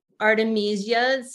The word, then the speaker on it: Artemisia: But also you said Artemisia. Am I saying that right? – Artemisias. Yeah. So like a lot of people know what wormwood or mugwort, those are artemisias.